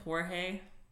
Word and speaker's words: Jorge: George Coelho. – I'm going to go ahead and say his name is Jorge.